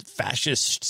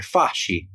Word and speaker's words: fasci: Like the SCI being pronounced like SHI in English, that's Italian. And that comes from the word fasci.